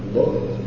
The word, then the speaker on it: Logos: Many people like to say Logos, but it's not Logos, because it's Lambda, Omicron, Gamma, Omicron, Sigma. Logos.